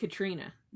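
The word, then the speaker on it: Caitríona: Yeah, it's spelled really weird, but I think it's just Katrina.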